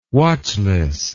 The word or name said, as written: watchless